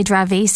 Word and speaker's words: Dravet: Yes, actually Dravet syndrome. – Dravet, is that how it's pronounced? – It's a French doctor who discovered it.